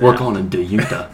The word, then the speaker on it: Dejounte: DeJounte Murray. – DeJounte. I didn't know if it was hard or it. – Soft J.